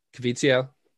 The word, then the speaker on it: Caviezel: Jim Caviezel. – Fuck. – What? It's literally how it's spelled.